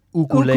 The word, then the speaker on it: ukulele: Which is pronounced ukulele. – Ukulele, I was just going to say. And he would call that out, too. – Why do you know that? – Because I've been to Hawaii. – Because it's, They pronounce only the vowels there.